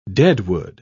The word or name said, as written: deadwood